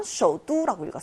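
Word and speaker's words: 쇼두 (首都): 수도는 베이징이지만 쇼두라고 읽었어요. 뚜라고 읽는다는 거 발음에 유념해 주시고요.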